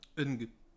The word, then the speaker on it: Ng: Alors, je sais pas comment prononcer le premier mot de son nom parce que ça s'écrit NG. – Eng. Je crois que c'est Eng. – Eng Man Ching, qui est donc le chef-op de Infernal Affairs 2 et 3.